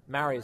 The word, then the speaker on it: Maori: So I read about how, like, a lot of the, like, indigenous population New Zealand, the Polynesian or Maori is how you say it, but I think they've changed the they've updated the pronunciation to better reflect the actual pronunciation of the word.